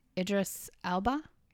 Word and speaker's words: Idris Elba: Not many people have watched the show, but it has Idris Elba. Did I say that right? – Yep.